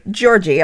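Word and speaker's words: Giorgi Thurzó: It's G-Y-O with two little dots over the O. R-G-Y. So, like, Giorgi, I think. – Okay. – And Thurzo is T-H-U-R-Z-O with a chicka over the O.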